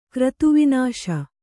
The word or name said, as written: ಕ್ರತುವಿನಾಶ